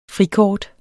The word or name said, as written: frikort